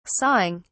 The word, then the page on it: sawing: British English